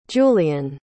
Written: julián

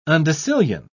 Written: undecillion

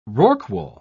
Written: rorqual

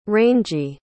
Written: rangey

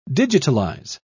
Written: digitalize

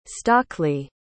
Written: stockley

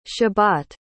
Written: shebat

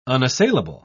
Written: unassailable

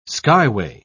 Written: skyway

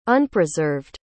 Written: unpreserved

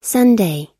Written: sunday

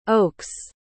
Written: okes

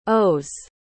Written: o's

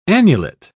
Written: annulet

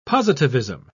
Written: positivism

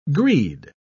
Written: greed

